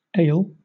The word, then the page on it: ail: British English